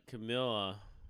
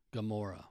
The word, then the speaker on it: Gamora: But Camilla, right? Am I saying that right? – Camilla? – Carmilla, the green chick. – Gamora.